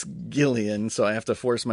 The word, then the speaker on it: Gillian: But I'm a big fan of Gillian Jacobs, and even though I feel weird calling her Gillian, but apparently that's how you say it. I always want to say Jillian, but...